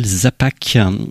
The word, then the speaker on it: Zapak: Et là, c'est un disque sur un label nippon qui s'appelle Zapak, comme ça se prononce avec un K à la fin.